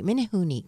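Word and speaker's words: Menehune: Yeah, it's M-E-N-E-H-U-N-E, Minnehune. Because in the Hawaiian language, you pronounce all the vowels, which I worked there in radio.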